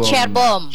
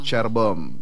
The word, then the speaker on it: Cerbom: Cerbom apa Cerbom bacanya? – Singkatnya Cerbom. – Cerbom.